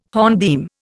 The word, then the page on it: hornbeam: Indian